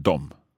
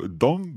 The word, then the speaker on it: de: Because it's D-E when it's written down, but we pronounce it dom. – Yes, because otherwise you sound like you come from the 1800s. – Okay. So dom bor I Helsingfors.